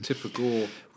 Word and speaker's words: Tipper Gore: Tipper Gore.